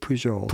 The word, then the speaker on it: Pujol: Well, no, if he's French, it would be Pujol. – Pujol? – Yeah, right? – P-U-J-O-L? – I think Pujol. – Pujol. – Yeah. So luckily, he's not Spanish. – Yeah, right. Okay. You're right. The J wouldn't be a H in French. So Pujol.